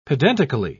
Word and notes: pedantically: British English

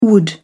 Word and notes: wood: British English

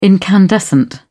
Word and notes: incandescent: British English

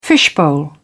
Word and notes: fishbowl: British English

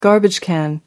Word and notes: garbage can: American English

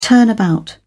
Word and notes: turnabout: British English

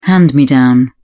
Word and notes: hand me down: British English

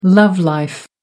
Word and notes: love life: American English

